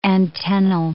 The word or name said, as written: antennal